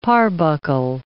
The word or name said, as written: parbuckle